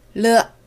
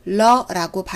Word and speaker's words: 了: 르 이고요. L, E로 표시돼요. 르, 르. 중국어의 한 글자 한 글자에는 여러 음과 입모양이 포함되어 있습니다. 익숙해질수록 러라고 발음할 수도 있지만 르라고 했을 경우 조금 더 그들의 발음 원리에 가깝습니다.